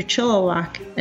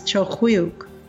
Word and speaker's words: Chilliwack: And while you're driving along the Kokahala, you might pass through Chilliwack, another Halkomelem word that's traditionally said as Chilkwiuk.